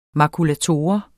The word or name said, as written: makulator